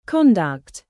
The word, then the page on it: conduct: British English; verb